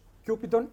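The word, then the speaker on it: Cupidon: «Εγκόλτε κιούπιντον πίπτε απ» και κούπιντον προφέρεται, ένας χρυσός κιούπιντον ερωτιδέας, σκεφτείτε το αγαλματάκι, «πίπτε out», σήκωνε το βλέμμα, μας κοίταζε, ύψωνε το βλέμμα μέσα σε ένα χώρο.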